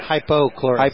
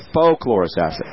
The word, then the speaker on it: hypochlorous: But there's the acid stream that contains hyperchlorous acid. Is that how you say it? – Hypochlorous. – Hypochlorous acid. Okay.